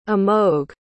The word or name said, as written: amogh